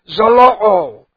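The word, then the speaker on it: zeloo (ζηλόω): The Greek word is zeloo. Z-E-L-O, long O.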